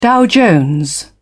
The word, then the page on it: dow jones: British English